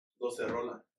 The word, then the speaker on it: docerola: Like the right way is docerola. – Docerola. – Yeah, but most people just call it requinto, you know.